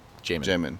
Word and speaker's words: Jamin: It's actually J, like J-mon. – J-mon. – But when you're pronouncing it, it's almost like Damon. So it's like Jamin.